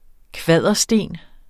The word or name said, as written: kvadersten